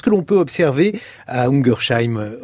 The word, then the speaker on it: Ungersheim: Alors, j'ai parlé de cette ville anglaise, Totnes, mais il y a par exemple une ville en France, Ungersheim. J'espère que je prononce bien ce mot, Xavier. – Ungersheim.